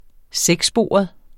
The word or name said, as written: sekssporet